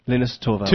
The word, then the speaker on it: Linus Torvalds: Linus Torvalds.